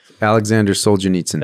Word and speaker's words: Solzhenitsyn: Alexander Solzhenitsyn.